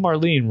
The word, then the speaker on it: Marlene: And the name Marlene rhymes with Harleen.